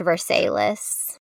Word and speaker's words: Versailles: And it's in a city in Kentucky called, it's spelled like Versailles, but it's pronounced Versailles.